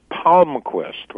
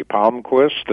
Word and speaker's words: Palmquist: It's Palmquist, like a palm tree. Palmquist, it's Swedish.